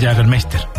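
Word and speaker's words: Jägermeister: Jagermeister.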